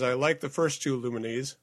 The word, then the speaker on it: Lumines: Is that how you say it, by the way? – I thought it was Lumines. – I don't know. – I've always heard Lumines. – All right, Lumines.